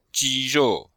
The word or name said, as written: ji1rou4